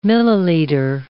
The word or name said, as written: milliliter